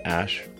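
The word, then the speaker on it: Æ: The AE is like pronounced Ash.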